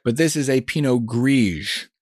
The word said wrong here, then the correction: Pinot Gris: Pinot Gris. – Pinot Gris, G-R-I-S, I apologize. – G-R-I-S, the right. S is silent because it is en français.